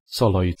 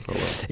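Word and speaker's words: Szalai: wie sie es aussprechen. Soloi. Soloi. Soloi. Adam Soloi. Also eher Soloi, hä? – Das Soloi, ist kein Sch Soloi, eher Soloi. Soloi. Soloi.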